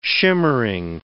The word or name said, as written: shimmering